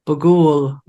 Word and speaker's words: Bughuul: Bughuul.